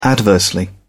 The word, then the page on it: adversely: British English